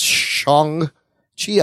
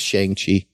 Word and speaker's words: Shang-Chi: It's pronounced Shang-Chi. I always thought it was Shang-Chi. I'm an hmm.